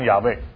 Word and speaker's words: 亚卫: 学术界有些人呢，他有一个看法呢，就是说这个神明呢也是知道的，就是说可以读成亚卫啊，读成亚卫，亚卫呢是比较简单的这个。